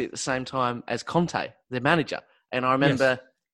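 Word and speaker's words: Kanté: And in English, it's Kante, and in French, it's Conte.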